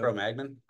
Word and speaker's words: Cro-Magnon: And then, I don't know how to pronounce that. I've heard of the Cro-Magnon. – Cro-Magnon. Yeah. Cro-Magnon Clovis is going to be heading to source.